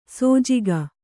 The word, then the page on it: ಸೋಜಿಗ: sōjiga